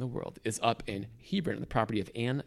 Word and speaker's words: Hebron: After the biblical Chevron, Hebron. – Yeah. – Which I've checked with you. It's a correct pronunciation.